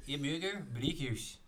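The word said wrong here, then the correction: Amager Bryghus: Zeg ik het goed? Ja, jij bent... Uh, Amager Broehuis.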